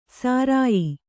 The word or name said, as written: ಸರಾಯೀ